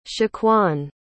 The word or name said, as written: shaquan